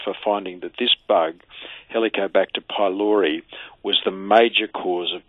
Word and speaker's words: Helicobacter pylori: Helicobacter pylori.